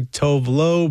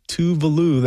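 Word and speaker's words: Tove Lo: I was always confused at how to say that artist's name until someone said, hey, just remember that it rhymes with Groovy Sue. So most people would say Tovlo, but it is Tuvalu. That's how it's pronounced. Here's a song that Tuvalu did with Stromae, De Fleurs.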